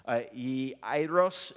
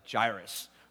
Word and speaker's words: Jairus: It says this, Jairus is the way that you'd probably properly pronounce it, but like I said, I'm just going to stick with the typical honky style, which is like Jairus, all right?